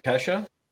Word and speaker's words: Kesha: Is it Kesha Keisha or Kesha? Kesha, Kesha I, I said it wrong.